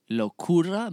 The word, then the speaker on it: locura: Locura. Am I saying that right? – Locura. – Locura? – L-O-C-U-R-A. – L-O-C-U-R-A. Locura. – Yes.